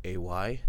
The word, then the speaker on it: Alay: Both of those you can find, just look up Rainy Days with Randy Alay. Last name is spelled Alay.